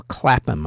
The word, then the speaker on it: Clapham: It will take place on August 28th in South London on Clamped or Clapton Common or Clapham, I should say, since they don't pronounce the H over there.